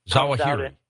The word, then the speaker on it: Zawahiri: Al-Qaeda is not going to believe this unless one of his lieutenants, like al-Ziri, I keep mispronouncing his name. – Zawahiri.